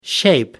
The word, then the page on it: shape: American English